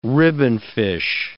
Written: ribbonfish

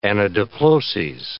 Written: anadiploses

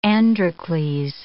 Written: Androcles

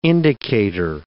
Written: indicator

